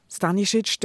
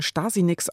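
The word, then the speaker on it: Stanišić: Stanisic, der sich darüber amüsiert, dass er auch schon Stasi-Nix ausgesprochen wurde, hat eben nicht nur über die Uckermark, sondern auch über seine Herkunft geschrieben.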